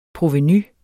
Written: provenu

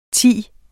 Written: thi

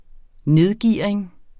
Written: nedgearing

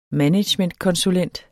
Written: managementkonsulent

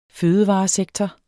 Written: fødevaresektor

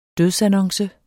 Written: dødsannonce

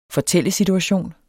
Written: fortællesituation

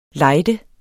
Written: lejde

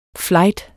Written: flight

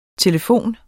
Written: telefon

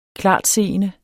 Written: klartseende

